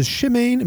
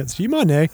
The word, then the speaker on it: Shimane: The nutritionist Erica Ungyal travels to Chimane. Am I pronouncing that right? Prefecture? – Chimane? – Chimane? – How's it spelled? – Shimane.